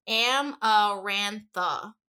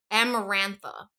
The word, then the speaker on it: Amarantha: But in reality, the pronunciation for this one is Amarantha. So Amarantha.